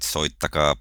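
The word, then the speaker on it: soittakaa: but they say Soitaka paranoid. So are you going to have paranoid, Black Sabbath's paranoid song on your playlist? – That is well pronounced, Thomas. You're proceeding well with your Finnish lessons.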